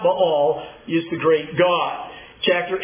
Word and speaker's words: Baal: Baal is the proper pronunciation there. I learned while I was in Israel, Baal.